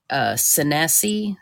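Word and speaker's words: Seneca: Now, they were attacked by another Indian tribe, and I believe it's pronounced the Senesi, or it's S-E-N-E-C-A.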